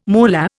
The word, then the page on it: moolah: Indian